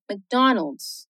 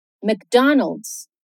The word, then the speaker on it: McDonald's: Se a gente fosse separar as sílabas de Donalds no ritmo do português, nosso instinto seria Donald's. Mas perceba que no inglês é Donald's. Donald's. McDonald's.